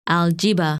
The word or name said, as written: Algiebba